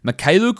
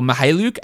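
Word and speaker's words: Mykhailiuk: Mikhailuk or Mihailuk, actually. I think that's how it's pronounced. I've always been saying that K, but I believe it's silent.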